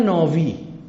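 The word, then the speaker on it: ناوی (נביא): یهودیا بهش گفتن ناسی، پیغمبرو میگن ناوی، نبی، به و تلفظ شده، نبی شده ناوی.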